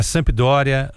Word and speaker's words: Sampdoria: Mas ele fez uma carreira praticamente inteira, né, na Itália, jogando no Empoli, no Frosinone, no Brescia, na Sampdoria, no Cesena. Tá certo a pronúncia? Vai me corrigindo aí. Sampdoria, Tá certo.